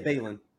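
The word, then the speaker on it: Baelon: Balon. – It. – Balon.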